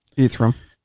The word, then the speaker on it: Ethereum: Ethereum. – Is that how you say it? – Ethereum. – Ethereum? That one.